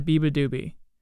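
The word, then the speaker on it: beabadoobee: Biba Doobie. That's how you say it. I used to not know how to say it.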